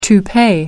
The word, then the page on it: toupee: American English